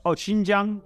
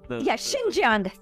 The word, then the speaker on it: Xinjiang: How do you pronounce it? I'm sorry. – Sorry, you're talking about Xinjiang? – Xinjiang. Sorry, I mispronounce it all the time. Okay, Xinjiang, and the U.S.